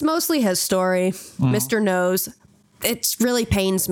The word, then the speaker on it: Noyes: And you're probably imagining that his last name is spelt like in a way that would seem like it's pronounced Noyes, but it's not. It's spelt N-O-Y-E-S. It's spelt no yes. Because this man can't right. make up his mind. So you'd think that it's pronounced Noyes. – Noyes. – Or Noyes. – I mean, Noyes. – But no, it's Noyes. I hate it.